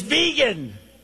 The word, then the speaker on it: vegan: It's not Vagan, it's vegan. I don't know about that stuff. I'm not into it. And he said, it's vegan. Please pronounce that word properly.